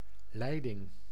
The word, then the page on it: pipe: American English; [pɑɪp]